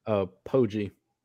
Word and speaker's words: Poggi: Poggi.